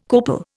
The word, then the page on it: copal: Indian